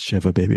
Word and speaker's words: Shiva Baby: That was you're not the one who mispronounced Shiva Baby like I did. So, Bruce Perky, it's Shiva Baby, of course.